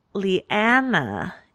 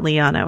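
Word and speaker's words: Lyanna: And, so I'm going to give that to like baby Ned, baby Brandon, baby Leanna is how they pronounce it. Not Liana, which is what I always say. Liana. – Oh, but Sansa says Liana. – Ugh, you guys, get your vowels straight.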